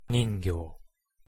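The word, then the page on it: ningyo: Japanese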